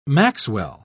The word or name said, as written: maxwell